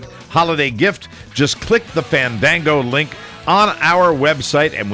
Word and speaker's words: Fandango: Fandango. – That's how you say it. – Fandango is your ultimate source for movie tickets and showtimes, theater listings, movie reviews, and coming attractions.